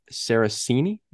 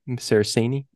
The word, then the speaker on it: Saraceni: Our next episode is Terror in the Sky, written by Steve Perry and Mark Saraceni. Saraceni, sorry. Pronounced that wrong, Mark.